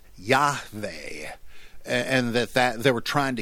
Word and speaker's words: Yahweh: The tetragrammaton is the name Yahweh. That's really, I think, how you're supposed to say it.